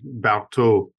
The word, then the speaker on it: Barthaud: Guylaine Barthaud. – Don't pronounce the D. Barthaud. There's no D on the end of that.